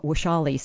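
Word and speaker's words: Vashali: I know my name, Washali, sounds like it's a W, but it's actually spelled with a V.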